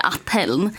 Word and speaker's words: Athärn: Ska betyda envis på jämska. Ni uttalar inte riktigt så. – Nej. Utan vi hittade ju mest, jag googlade fram lite liksom jämtländska ord, och då har ju vi tolkat att vi säger Athärn.